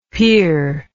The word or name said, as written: pir